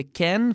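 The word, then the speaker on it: Kean: det är ju Moise Keane. – Ja. – Som i Italien blev Moise Ken, för att italienarna uttalar som de gör.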